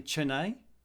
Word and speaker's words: Chennai: She's joining us from, I think I'm saying it correctly, Chennai. – Perfect. – Chennai, yes, India, in southern India.